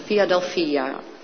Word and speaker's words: Philadelphia: Ik kijk naar Manfred, doe ik nou de klemtoon goed? Ja, ik doe de klemtoon goed. Philadelphia.